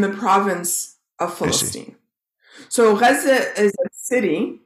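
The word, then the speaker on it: Gaza (غزة): Was Gaza, which in Arabic is pronounced Gaza, did I say that correctly? – Gaza.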